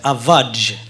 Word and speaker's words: Avage: Jag måste bara det är svårt att säga, men det heter Avaj när man uttalar Avaj!